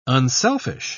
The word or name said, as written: unselfish